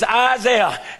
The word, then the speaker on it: Isaiah: My mama told me, she said, your name is Isaiah.